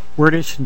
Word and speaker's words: Wertish: Wordish, W-E-R-T-I-S-H.